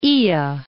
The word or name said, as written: Oea